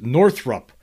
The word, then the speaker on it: Northrop: Just FYI, it's pronounced Northrop." And he's talking about here is North. I guess when I'm talking about Northrop, I'm always calling it Northrop Grumman. Right? It's actually Northrop Grumman.